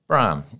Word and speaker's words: Brougham: Is that how you pronounce that surname? – Brougham. Yes. Brougham. Yes. Brougham. But okay. I think locally, broom works. – All right. Yes. Broom as in sweeping. – As in sweep the broom.